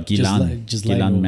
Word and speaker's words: Ghislaine: Yeah, Ghislaine Maxwell.